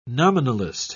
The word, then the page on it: nominalist: British English